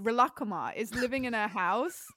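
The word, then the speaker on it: Rilakkuma: It's pronounced Relakuma because it's Japanese.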